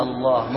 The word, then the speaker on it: الله: أي نعم، صارت لام واحدة مشددة. أنت كم تنطق لامات الآن؟ – يعني كلمة الله ما هو الإله. الله. – أي نعم. – فيها لامين. – لكن الأول ساكن والثاني مشدد.